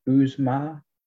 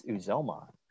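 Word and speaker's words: Uzomah: Is it Uzma? – It's Uzoma.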